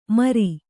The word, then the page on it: ಮರಿ: kannada